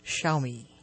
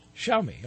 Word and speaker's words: Xiaomi: So, by the way, AXIAOMI is pronounced Xiaomi. – Xiaomi, okay.